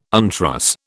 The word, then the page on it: untruss: British English